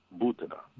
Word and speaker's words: Butina: How is her name pronounced? Because I've never heard her say it. – I would say Boutina. – Butina. Like Putin, but Butina.